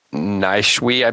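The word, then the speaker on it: Nijhuis: And it's by Mich- Michelle Nishwe, I believe is how you say her name. I, I, I should have double checked that before I said it, but it's N I J H U I S.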